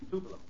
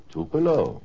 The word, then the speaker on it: Tupelo: Do I pronounce that correctly? Is it Tupelo? – Tupelo. – Tupelo. What is it? Is that the temperature of the place? T-U-P-E-L-O, Tupelo, huh?